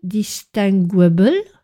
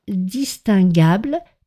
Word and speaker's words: distinguable: Donc je ne prononce pas distinguable sous une influence anglo-saxonne, mais bien distinguable qui s'écrit D-I-S-T-I-N-G-U-A-B-L-E.